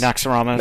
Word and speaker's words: Naxxramas: Other than that, I've been playing Curse of, how do I say this, Neuromaxus? Naxus? – Naxxramas. – Naxximagus. – You're perfect on. Yes.